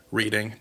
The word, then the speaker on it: Reading: Reading. I believe that's how they pronounce it. Reading.